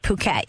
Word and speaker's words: Phuket: But when we were in Thailand, first we went to Phuket, and it's great. Wait, that's how you pronounce that? Phuket, yeah. – I was like, fuck it, right?